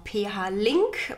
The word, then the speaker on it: Flink: Also Flink ist tatsächlich richtig. Und ähm, manche sagen auch PH-Link, was der ganzen Sache gar nicht mal so äh, fern ist, da es der Link zwischen der Philips-Universität und der Praxis ist. Aber Flink ist definitiv richtig ausgesprochen.